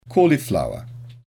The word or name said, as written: cauliflower